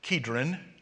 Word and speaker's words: Kidron: I know it's spelled with a C, but it's pronounced with a K, Kidron.